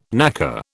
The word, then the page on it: knacker: British English